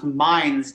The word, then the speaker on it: Mainz: Yeah, I am in, it's actually pronounced Mainz, Germany.